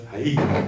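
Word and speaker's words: Ha'i (הוא): The Krinik said, we pronounce it as Ha'i, but it's written as Hu.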